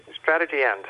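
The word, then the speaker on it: Strategy&: How do you it's say the uh, name? Strategy, Strategy End, formerly Booz and Company until a few weeks ago, but now uh, Strategy End.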